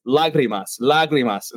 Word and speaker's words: Lágrimas: Because I think, Fonz, I think I told you this story, but you know, I kept calling it Lágrimas, right? And everyone at the distillery was like, no, man. Like your white dude Spanish is not working right now. It's Lágrimas.